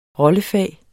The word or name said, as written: rollefag